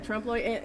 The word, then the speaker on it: trompe-l'oeil: And this wall treatment, these kind of trompe-l'oeil, is that how you say it? – Yeah, trompe-l'oeil, and also bas-relief.